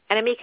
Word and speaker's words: Annemiek: Annemiek, am I saying your name correctly? – It's perfect.